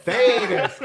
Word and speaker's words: Thetis: Thetis.